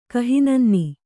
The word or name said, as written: ಕಹಿನನ್ನಿ